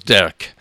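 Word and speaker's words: Dearg: Alors ça s'écrit Derg, mais ça se prononce Derk. Me demandez pas, c'est l'orthographe irlandaise, jamais rien compris.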